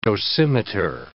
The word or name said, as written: dosimeter